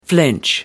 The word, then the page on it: flinch: British English